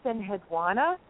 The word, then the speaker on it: Enheduanna: I would say your pronunciation isn't any more right than mine. Mine is Enheduanna. But you pronounce the U very specifically, and I don't see why you either would be right or wrong, if you know what I mean.